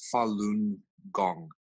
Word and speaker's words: Falun Gong: So when Falun Gong was introduced into the general public of China, and it's pronounced Falun Gong, it spread like wildfire, Paul.